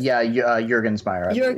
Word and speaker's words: Juergensmeyer: Is that how you say it? – Juergensmeyer.